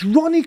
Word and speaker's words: Ronnie: He said, Ranny Corbett. and I went, it's Ronnie Corbett. He went, yeah, Ronnie Corbett.